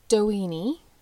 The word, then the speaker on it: Doheny: Doheny, I've heard it pronounced multiple ways, but that's how I'm going to pronounce it because it sounds like weenie.